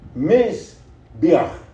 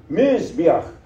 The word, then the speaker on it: Mizbeach: The word for altar is, and I hope I do it justice, Mizbeach. That's how they pronounce it. Mizbeach. M-I-Z-B-E-A-C-H.